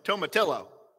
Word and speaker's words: tomatillo: I would say tomatillo, like a tortilla.